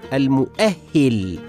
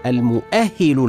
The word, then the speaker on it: المؤهل: من كلمات اللغه العربيه المعبره عن شهاده جامعيه ينالها طالب العلم كلمه المؤهل العالي بكسر الهاء ولكن البعض ينطقها بفتحها وهذا خطأ شائع والصواب قولنا المؤهل العالي